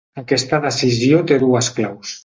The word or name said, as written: claus